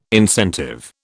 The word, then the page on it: incentive: British English